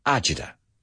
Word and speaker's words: agita: it's so common, this idea in Italian American, that the original word is acida. I don't know if I pronounce it the right way, but we say agita.